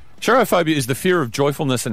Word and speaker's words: Cherophobia: What's interesting is the performance has a name, which is Cherophobia. I think I'm pronouncing that correctly. – Cherophobia? – Or Cherophobia, as in C-H-E-R-O. Phobia, right? – Which is a fear.